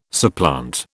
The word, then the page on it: supplant: British English